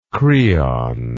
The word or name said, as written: kreon